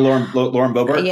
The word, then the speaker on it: Boebert: And like we saw, you know, prior to this, like the likes of, I don't even know how to say her name, Bobart. Is that it? Lauren Boebert? – Lauren Boebert.